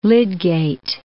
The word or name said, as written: Lydgate